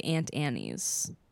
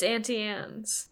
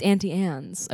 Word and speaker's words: Auntie Anne's: Natalie's now saying that I say Aunt Annie's. And that it is. – Yeah, it's Auntie Anne's. – It's Auntie Anne's.